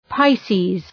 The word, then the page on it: Pisces: {‘paısi:z}